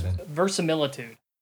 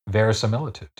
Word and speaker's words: verisimilitude: It's verisimilitude. – Verisimilitude, yeah. – Wasn't sure if I was saying that right. – Well, you're not, but it's verisimilitude.